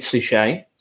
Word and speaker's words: Suchet: And it does star David Suchet, who I think I pronounced that right. It's not Suchet. It's not a Hyacinth Bouquet situation, is it? – No, I think that's right. See, I think that's right. I think you've got that spot on, yeah.